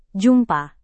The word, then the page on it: jumpa: Indonesian